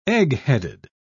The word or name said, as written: eggheaded